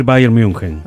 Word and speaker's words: Bayern München: porque en la Argentina mucha gente simplifica ese nombre y dice Múnich o Múnich. – Claro, es una mezcla muchas veces. – ¿Y cómo es la pronunciación correcta? – Y habría que decir Bayern München.